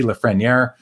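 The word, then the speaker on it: Lafrenière: I got to remember how to say it. It's Alexi Lafreniere.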